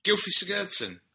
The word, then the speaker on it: Gylfi Sigurdsson: Gilfie, what's I think Kevin Phillips has got the right pronunciation of his name. Gylfi Sigurdsson. Yeah, he's the boy's doing good.